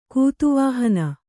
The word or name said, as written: ಗತಿವಿಹೀನೆ